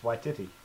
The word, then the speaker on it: Waititi: I've been saying it wrong my whole life. – Wait, really? – Yeah. – It's Waititi. – Waititi. – Damn. – Not Waititi.